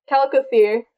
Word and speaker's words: Chalicothere: I don't know if the CH is pronounced or not. If it is, it's Chalicothere. If it's not, it's Calicothere.